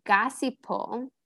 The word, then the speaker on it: gossypol: Um, but anyway, so I may butcher the pronunciation of this, but Gossipol, Gossipol, have you heard of this? – sounds correct, your pronunciation.